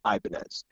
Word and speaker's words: Ibanez: But yeah, everyone always assumes Ibanez because of Raul, but it's actually Ibanez.